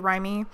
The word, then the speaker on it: Raimi: Sam Raimi, I think it's pronounced. It might be Raimi.